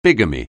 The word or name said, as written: bigamy